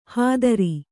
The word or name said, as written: ಹದರಿ